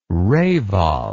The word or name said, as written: reval